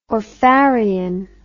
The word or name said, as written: orpharion